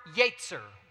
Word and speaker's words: yetzer: Now, that word is the word yetzer. I think that's up. Next slide. Yeah, yetzer. Go ahead and say yetzer. Yetzer, it's how it's pronounced.